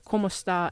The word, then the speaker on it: kumusta: Sounds exactly like the Spanish phrase ¿cómo está? If you say it quickly, ¿cómo está? And in Spanish, ¿cómo está? Means how are you, but ¿cómo está in Filipino, which is spelled K-U-M-U-S-T-A, is, like I said, hello.